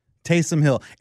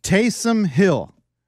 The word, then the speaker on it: Taysom Hill: After his performance yesterday, I will now pronounce his name correctly. Taysom Hill.